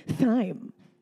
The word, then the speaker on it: thyme: Why is it thyme and it's spelled T-H-Y-M-E? It's thyme. – Somebody black did it. No, I'm just kidding. – No. Somebody white. Like, well, the H is silent, but it's thyme, like T-I-M-E.